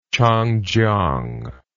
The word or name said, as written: Changjiang